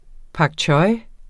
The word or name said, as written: pak